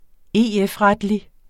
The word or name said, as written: EF-retlig